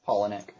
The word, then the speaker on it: Palahniuk: Palahniuk.